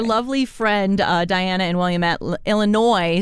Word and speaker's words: Illinois: Thank you to Sarah in Colorado Springs, Greg in Manitou Springs, Diana in Williamette, Illinois. I'm so sorry. – Illinois.